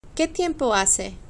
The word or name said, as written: que tiempo hace